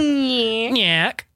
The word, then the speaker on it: Nyack: Nyack.